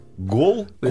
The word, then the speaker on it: Голконда: Голконда. – Я уверен, что вы неправильно произносите. – Нет, все правильно.